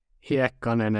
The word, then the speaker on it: Heikkanen: Uttalar man ditt efternamn så? Är jag korrekt ute då? Heikkanen är det korrekta uttalet.